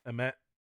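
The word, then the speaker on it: Ammit: But then they changed the god from Amun-Ra to, what is it, Amit? Am I saying that right? – Yes. – Amit? – Amit.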